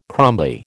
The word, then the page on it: crumbly: American English